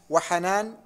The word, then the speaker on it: حنان: نعم وحيان الأسدي بالياء المشددة المثنات من تحت وحنان بالنون الخفيفة الأسدي والله أعلم.